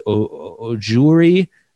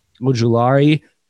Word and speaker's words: Ojulari: Aziz Ojulari. I mispronounced that. I screwed that up. But Ojulari, insane game, closed it out.